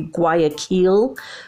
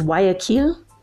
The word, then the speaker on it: Guayaquil: So if you're American, you can pronounce it in an English way. It'd be Guayaquil or Guayaquil, maybe. But it's in Spanish, it's Guayaquil.